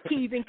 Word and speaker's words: Kivin: Yeah, Kivin, K-I-V-I-N. Kivin.